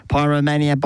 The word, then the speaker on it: Pyromania: That's how you pronounce it. Pyromania by Dressed.